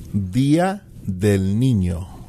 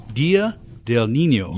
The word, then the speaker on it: Día del Niño: Día del Niño. – Día del Niño.